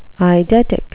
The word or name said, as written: eidetic